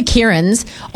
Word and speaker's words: Kieran: K-I-E-R-A-N. – A-N. So you're A-N. And how do you pronounce that? – Kieran. – Yeah, beautiful. Okay. I can hear it. – Yep, said perfectly.